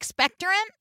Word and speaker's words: expectorant: Yes, it's an expectorant. Is that right? Is that how you say it? – Expectorant? – Thank you. – Expectorant. – I put the wrong emphasis on the wrong syllable.